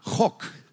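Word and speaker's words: hok (חק): Cuando usted lee la palabra estatutos en español, en hebreo es la palabra hok. La pronunciación es K-J-O-C.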